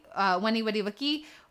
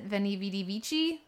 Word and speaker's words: Veni Vidi Vici: Then Noel's going to talk a little Greenleaf, Weni Wedi Wiki, or the way everybody else pronounces it, Veni Vidi Vici, which is not the correct Latin way, nerd.